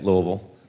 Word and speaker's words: Louisville: That's how you say it, Louisville.